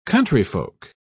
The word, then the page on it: countryfolk: British English